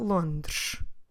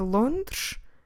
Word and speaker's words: Londres: C-I-D-A-D-E. Cidade. Lisboa é uma cidade mais pequena do que Londres. Londres is the Portuguese word for London. Como se escreve Londres? L-O-N-D-R-E-S.